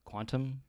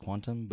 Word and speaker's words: Qtum: So today I was wearing a Qtum, Quantum. I think you know, baby. – It's pronounced Quantum, but it's Qtum is the symbol.